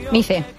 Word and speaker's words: MICE: ¿Qué supone para vosotros que el corto haya llegado o que esté compitiendo en la Mostra Internacional de Cinema Educativo, el MICE, o MAIS, no sé cómo se pronuncia? – MICE. – MICE, pues venga, el MICE.